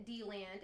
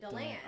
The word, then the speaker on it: DeLand: Come to find out, the natives of D lands actually pronounce it D land, and everyone else pronounces it Deland.